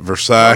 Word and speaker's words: Versailles: That's how you say that. Versailles. – Oh, wow. – It's not pronounced for sales.